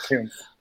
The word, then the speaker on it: Kuntz: I think he pronounced it Coons.